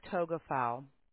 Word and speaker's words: Togafau: My name is Tina Togafau.